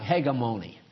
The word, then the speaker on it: hegemony: It's spelled, looks like hegemony. But when you see it, it's pronounced hegemony, and it means leadership.